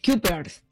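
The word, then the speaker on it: Cuypers: A ver, Kuipers, así se escribe. Es C-U-Y. – Kuipers. – Kuipers. Ah, ok.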